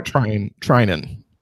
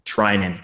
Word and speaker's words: Treinen: Another name I think that would be interesting, not a lefty, but Blake Treinen, I'm trying to say his name from the Athletics, the dude that's on fire. – Treinen. – Trinan, thank you.